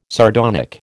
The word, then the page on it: sardonic: American English